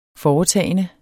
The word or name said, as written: foretagende